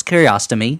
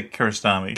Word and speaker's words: Kiarostami: It's a movie directed by Abbas Kiarostami. Is that how it's pronounced? Like colonoscopy? – What? – Yeah. Kiarostami? – I would say Kiarostami.